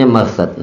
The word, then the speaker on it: Marthad: Oh, bacanya Marthad. Nah, anak Aba Marthad bacanya.